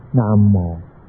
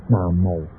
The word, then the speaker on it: 南无: 南无，不能念成南无。现在我们念这个字南方的南，有无有无，你怎么念法呢？就错了。这个名号是从印度梵文音译过来的，一定要念开堂之后的音才是准确。